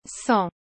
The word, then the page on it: cent: ソン